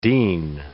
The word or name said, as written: dean